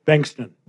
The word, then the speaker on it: Bengtsson: Bengtsson. Yeah, it's a Swedish name.